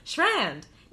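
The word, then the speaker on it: Shrand: My name is Sophie Schrand. My name is... – Please don't interrupt. Sophie! What's your last name? – Schrand! That's spelled S-H-R-A, N as in Nancy, D as in David.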